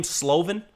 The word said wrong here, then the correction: Slovis: Keaton Slovis.